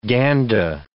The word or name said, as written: Ganda